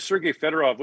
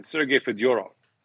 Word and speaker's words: Fedorov: So Russian names, for the most part, the accent is on the middle syllable, which is interesting because if he'd come over 20 years later, Sergei Fedorov would have been Sergei Fedorov. That's how you say his name in Russian.